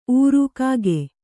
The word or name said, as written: ಊರು